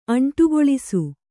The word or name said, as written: ಅಂಟುಗೊಳಿಸು